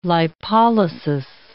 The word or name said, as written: lipolysis